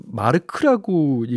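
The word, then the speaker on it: Mark: 그래서 오늘은 어, 마크 조단의 예, The Best Part of My Life 골라봤습니다. 이거를 마르크라고 읽기도 하나 봐요. 마르크 조단이라고.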